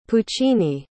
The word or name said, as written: puccini